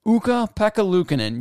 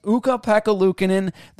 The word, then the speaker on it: Ukko-Pekka Luukkonen: And the success stems in part from incredible goaltending from Ukko-Pekka Luukkonen. Yes, I said that right. Ukko-Pekka Luukkonen,